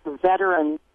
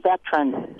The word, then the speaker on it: veteran: People who pronounce veteran as veteran, two-syllable word. It's three syllables.